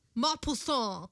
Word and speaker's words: Maupassant: I think if I'm remembering how the name is spelled, it's Montpoussin. – Montpoussin. – Mont. – Mont. – Mont. More nasal. Mont. Montpoussin. – Poussin. Maupassant. – Not my pussy, Maupassant.